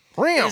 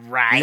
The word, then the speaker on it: Rian: R-I-A-N. – Yeah. – How do you pronounce it? I say. – Rion. – Is it Rion? – Rion. – Or is it Ryan? Rion.